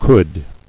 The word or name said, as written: Could